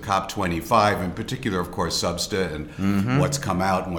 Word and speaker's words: SBSTA: And the acronym for that, SBSTA, is pronounced SUBSTA, part of the Secretariat of the UNFCCC.